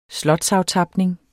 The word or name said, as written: slotsaftapning